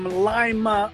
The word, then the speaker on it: Lima: Hey, good morning there from Lima, Ohio. That's Lima with a long I. It's not Lima.